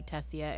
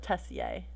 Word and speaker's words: Tessier: I mean, if it's pronounced like the French way, it would be Tessier. – All right, let's go with Tessier.